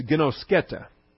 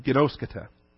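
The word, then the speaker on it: ginosketa (γινώσκετε): Now, as it appears in the text, it's ginosketa. Right here, ginosketa. G-I-N-O. That's the long O, the omega. It looks like a W. G-I-N-O-S-K-E-T-E, ginosketa.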